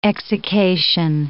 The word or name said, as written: exsiccation